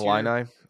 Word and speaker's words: Illini: Illini.